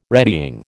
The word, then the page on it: retying: American English